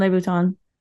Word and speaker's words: Louboutin: Now, it's a little bit shocking because I got that email from Marvel also that they had teamed up with, how do you pronounce it? – Christian Louboutin.